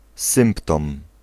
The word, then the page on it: verschijnsel: /vər.ˈsxɛi̯n.səl/